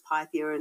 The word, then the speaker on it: Pythia: And you're probably right, it's probably pronounced Pythia in the Greek. I guess for me, in my own head, it's Pythia, and that's my Australian slang coming into it.